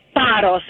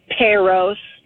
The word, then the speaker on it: Paros: And I wanted to tell her, please, it's Paros. It's not Paros. Please and thank you. – Oh, okay. – Girl. Sit down. – All the Greek out there that have been wasting Paros.